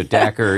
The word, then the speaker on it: Dacre: But Rhymes with cracker. Dacre Keltner. – Okay. So Dacre, yeah.